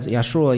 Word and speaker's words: Yeshua: Salvation means it's pronounced as Yeshua in Hebrew.